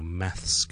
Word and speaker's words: math: 咁但係英國咧通常叫 maths 嘅 maths 有 s 嘅 maths maths。順便講一講佢發音啊，有啲難嘅 math math OK th 音脷尖鳳喺上面嗰排門牙嘅下方輕輕出氣 math，跟住我線翻入去 s 音個位時時沙沙嗰個位。